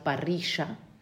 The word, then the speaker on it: parrilla: Entonces, nosotros vamos a pronunciar en Uruguay parrilla, porque pronunciamos la doble L como sh.